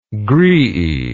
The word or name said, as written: graeae